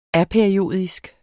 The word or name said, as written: APERIODISK